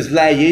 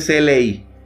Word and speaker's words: SLI: No dicen Sly, ellos dicen SLI.